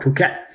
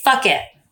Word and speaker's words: Phuket: We went to an island just off of Thailand called Phuket, which was worse. Or I like to say, fuck it. – Yeah, that's not how it goes. That's just how you say it.